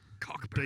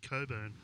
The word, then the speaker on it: Cockburn: It's spelled C-O-C-K. – Cockburn. – B-U-R-N. And only people from Coburn say Coburn. – Yeah. – But the rest of WA definitely says Cockburn.